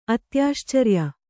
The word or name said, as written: ಅತ್ಯಾಶ್ಚರ್ಯ